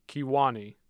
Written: Kewanee

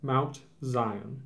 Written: Mount Zion

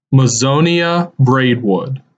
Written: Mazonia Braidwood

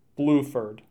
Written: Bluford

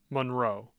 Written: Monroe

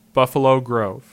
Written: Buffalo Grove

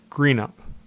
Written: Greenup

